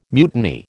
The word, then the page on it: mutiny: American English